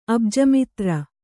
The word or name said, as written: ಅಬ್ಜಮಿತ್ರ